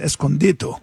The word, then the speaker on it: Escondido: small correction. It's pronounced Escondido.